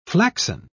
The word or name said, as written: flaxen